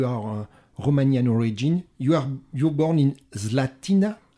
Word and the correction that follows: Slatina: I am right in your pronunciation. – Yes, it's called Slatina.